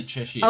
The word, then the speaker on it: Cheshire: It is Cheshire like oh. the cat, but I say Cheshire.